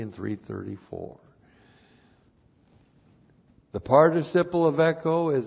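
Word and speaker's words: echo (ἔχω): Now echo, looks like this, E-C-H, long O, echo.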